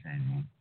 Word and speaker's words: Carignan: Some of them didn't tear it up, so we got Carignan around that is old vine. I've seen some old vine Carignan. Carignan is C-A-R-I-G-N-A-N, not Carignan, it's Carignan.